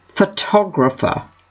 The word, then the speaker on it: photographer: Photographer. The stress is on the second syllable.